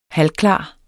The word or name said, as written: halvklar